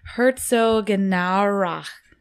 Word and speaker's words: Herzogenaurach: Herzogenaurach. It's like that, the CH at the end.